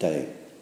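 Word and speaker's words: Te: Because this, this second book, the sequel, is called The Day of Piglet, T E, or pronounced Day.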